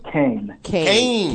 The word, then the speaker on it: Kayne: Kane. – K-A-Y-N-E.